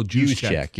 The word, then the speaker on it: Juszczyk: Kyle Juszczyk.